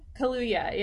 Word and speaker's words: Kaluuya: I chose Daniel Kaluuya. How do you say his last name? Kaluuya, yeah.